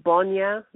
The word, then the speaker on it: Bonya: I'm actually writing another book, and it's called Bonya, which is spelled B-O-N-Y-A. It's Bonya, a father's covenant, because that's where my father came from.